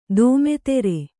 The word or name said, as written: ದೋಮೆತೆರೆ